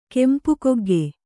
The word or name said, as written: ಕೆಂಪುಕೊಗ್ಗೆ